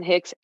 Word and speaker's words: Hicks: So you can find me at Allison Hicks everywhere. But here's the kicker. My name is spelled absolutely wild. So it's A-L-L-Y-C-I-N and then just normal Hicks, H-I-C-K-S.